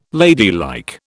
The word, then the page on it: ladylike: British English